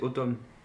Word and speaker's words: Autumn: On a ensuite Atun Reezer, donc je ne sais pas si je prononce correctement. – C'est Autumn.